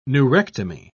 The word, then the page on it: neurectomy: British English